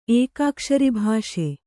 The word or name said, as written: ಏಕಾಕ್ಷರಿಭಾಷೆ